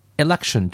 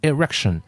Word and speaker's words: election: 可是因为日本人他读不好那个 l 跟 r 啊，日本人要么能区分其中的 r，要么能区中区分的 l，但是两个不一定能区分的很清楚，所以有人会把 election 读成 erection。